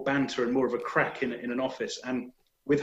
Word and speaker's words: craic: in Ireland they call it crack. It's spelled slightly differently, C-R-A-I-C.